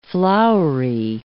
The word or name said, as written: flowery